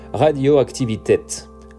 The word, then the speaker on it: Radioaktivität: On continuera avec un de mes groupes préférés, sans qui les musiques électroniques n'auraient pas été les mêmes, Kraftwerk et son cultissime Radioaktivität. Oui, je l'ai bien prononcé ainsi, car pour leurs albums, plusieurs versions existent, soit en allemand, soit en anglais.